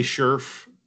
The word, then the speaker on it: Scherff: Did I say that last name wrong? – I would say Scherff. – Scherff? – I think you were close. I'm going to give you half credit.